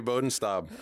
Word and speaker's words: Bodenstab: Do I pronounce that name correctly, Nate? – Yeah, that's pretty good. Nate Bodenstab.